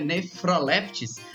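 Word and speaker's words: Nephrolepis: Ele disse neproleptes, né, na realidade é nefroleptis. PH tem som de, de F, né? Igual farmácia antigamente, não é mesmo? Então, nefroleptis exaltata.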